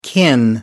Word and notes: kin: American English

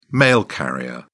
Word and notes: mail carrier: British English

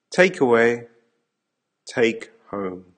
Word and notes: take away take home: American English

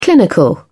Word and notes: clinical: British English

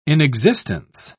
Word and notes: inexistence: British English

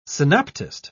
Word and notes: synoptist: British English

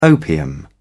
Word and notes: opium: British English

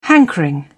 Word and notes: hankering: British English

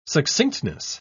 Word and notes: succinctness: British English

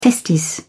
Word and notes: testis: British English

